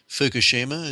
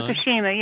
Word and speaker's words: Fukushima: And I'm thinking in particular of the, I think I'm pronouncing this correctly, Fukuyama? – Fukushima, is that what you've got in mind? Fukushima, yes, that's what I had in mind.